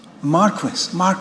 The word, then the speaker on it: Marquess: Is it Marquis of Bute? Marquis, right.